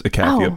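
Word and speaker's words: Icathia: I was pronouncing it Ikatha. – Ikatha, because there is Malzahar has a quote where he says Ikathia beckons. – That's probably right then.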